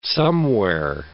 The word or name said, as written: somewhere